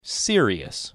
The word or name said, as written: Sirius